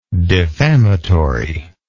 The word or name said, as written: defamatorily